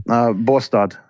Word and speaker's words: Båstad: And Stockholm or Bast, is that how you say it? – Båstad.